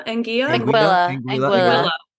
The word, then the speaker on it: Anguilla: Anguilla.